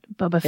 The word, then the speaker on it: Boba Fett: Is that how you say it? Boba Fett. – Boba Fett. – Yep, there you go.